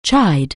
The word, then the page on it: chide: English